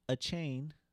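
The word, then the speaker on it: Achane: Devon A-Chain. A-Chain. – A-Chain. We're going to go with A-Chain. He said that's how you pronounce it when he was in college.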